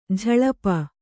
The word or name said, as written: ಝಳಪ